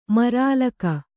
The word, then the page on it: ಮರಾಲಕ: marālaka